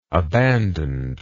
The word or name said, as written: abandonedly